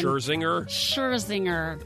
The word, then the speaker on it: Scherzinger: Nicole Schlesinger. – Robin Thicke. – Nicole Schlesinger. – I can never say her last name. – Scherzinger. That's not even close to Schlesinger.